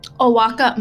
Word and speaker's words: Oaxaca: Oaxaca, Mexico.